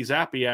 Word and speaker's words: Zappe: It's how you pronounce his name, correct? Did I say that Zappe. Correct? That, you got it.